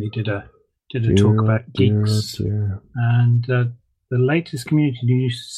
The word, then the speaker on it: Guix: Geeks. That was Geeks, by the way. – G-U-I-X. – Oh, Geeks.